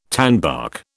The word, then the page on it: tanbark: British English